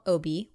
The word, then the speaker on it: Obeah: And it's called Obi, which is spelled O-B-E-A-H, just in case people want to look it up.